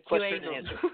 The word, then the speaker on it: QAnon: So I just pronounced it QAnon.